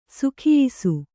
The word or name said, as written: ಸೊಗಯಿಸು